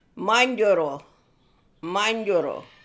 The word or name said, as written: Manyjoorroo